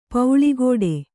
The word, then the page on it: ಪೌಳಿಗೋಡೆ: pauḷI gōḍe